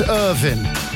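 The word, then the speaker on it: Irvine: Irvin. It's pronounced Irvin.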